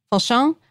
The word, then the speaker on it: Fauchon: And what changed is that in the 1980s, this Parisian patisserie how would you say that? – Fauchon.